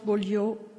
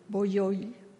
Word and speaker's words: Bolyai: Janos Bolyai, sorry for the pronunciation, Bolyai International Mathematical Prize for his book Frobenius Manifolds, Quantum Cohomology and Moduli Spaces.